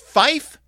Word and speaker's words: five: In NATO radio telephony, five is pronounced fife to avoid confusion with similar sounding words.